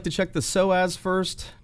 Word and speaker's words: psoas: If you're listening at home, that's spelled with a silent P. If you want to look it up, it's spelled P-S-O-A-S.